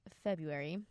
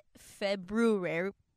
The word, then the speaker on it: February: Well, on the topic of love, we decided for the month of February. I have a question. I hope this is not dumb. – What? – Is it February? No, that's wrong. there's an R. It's a silent R. February.